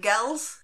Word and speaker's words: Gels: Gels. – Are we saying it right? Gels? – Gels. – It sounded like girls, but you kind of take away the R a bit.